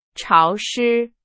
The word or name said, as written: 潮湿